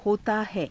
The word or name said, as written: hotaa hai